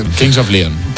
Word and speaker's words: Kings of Leon: Que es King of Leon, of Lion, no sé cómo se dice, la verdad, porque... – Kings of Leon.